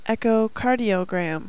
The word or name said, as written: EKG